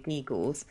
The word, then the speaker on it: geegles: they were kind of trinkets and sort of like little bits of trumpery finery, geegles. But you pronounce them geegles, which is even better. Is it ge- geegles or you say geegles? – Geegles.